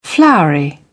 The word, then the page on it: floury: British English